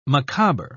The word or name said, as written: macabre